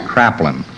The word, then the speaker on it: Kraepelin: I think it's spelled Kreplin, but it's actually pronounced Kraplin.